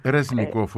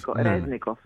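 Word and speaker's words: Ρεζνικόφ (Рєзніков): Ρεζνικόφ, ο Υπουργός Άμυνας. – Ρεζνικόφ, ναι, σωστά, του Υπουργού Άμυνας του κ.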